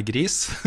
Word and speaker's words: Gris: Vi har funnet ut at det er gris, -hmm. som betyr grå på spansk.